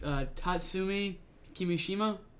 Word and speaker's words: Tatsumi Kimishima: So, his name is the old one, not the new one, was Tatsumi Kimishima.